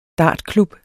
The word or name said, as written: dartklub